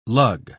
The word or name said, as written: lug